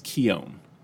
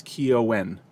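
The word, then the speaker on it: Keoghan: The Irish pronunciation is Keown, and when I say Keown, it's Keo-n, but it's said fat, quickly, Keown.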